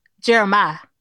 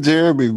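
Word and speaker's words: Jeremih: That nigga named Jeremy, bro. – Yeah. – No. – Everybody been saying that man name wrong for years.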